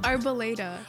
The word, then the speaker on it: Arboleda: Arboleda.